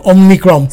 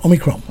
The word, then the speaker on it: Omicron: I was saying Omicron. – Speak for yourself. You couldn't say the name properly. – And then, you know, I kind of realized it's Omicron.